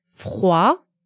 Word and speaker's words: froid: Yes, for example, the word froid, F-R-O-I-D, ends with D, and that means cold. – Say it one more time for us. Notice there's no D sound at the end. – Froid. – Froid. The other word we have is sang, which is blood, S-A-N-G, but there's no G pronounced.